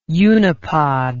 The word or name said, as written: unipod